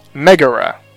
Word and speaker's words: Megara: Megara.